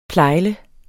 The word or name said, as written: plejle